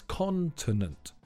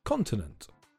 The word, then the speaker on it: continent: The stress is on the first syllable, so it's continent. Normally, continent.